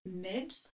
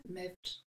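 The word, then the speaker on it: Medb: Yeah, I've heard it pronounced Med. – Med. – Med. Which is, I'm trying to say the BH. – Yeah. – Mevd. Mevd.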